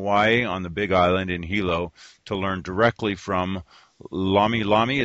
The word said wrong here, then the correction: lomi lomi: Is that pronounced correctly? Or Lomi Lomi? – Lomi Lomi.